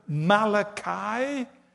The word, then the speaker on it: Malachi: Malachi, chapter 4, verse 1. Check it out sometime. That's how you pronounce it, Malachi.